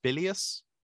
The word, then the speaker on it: bilious: Is it pronounced bilious? Yeah. Okay.